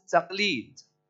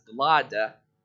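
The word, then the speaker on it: taqleed (تقليد): Our emulation of them is normally known as taqleed. Taqleed, if you look at the word which Iraqis will sometimes pronounce as glada, is normally a chain which is put around somebody's neck.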